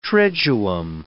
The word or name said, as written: triduum